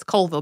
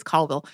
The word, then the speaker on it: Colville: Two, I will definitely admit that I said Colville as Colville in my head before going to look it up as a just in case, because it is spelled like you would think just based on patterns of, you know, how words are often pronounced in English, that it's Colville, but it is Colville.